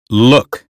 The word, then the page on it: look: American English